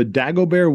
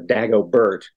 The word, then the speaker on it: Dagobert: And number 5 on that list is the Dagobert wooden toilet throne, named for a 7th century French king. – Which explains why you went with the French pronunciation instead of Dagobert.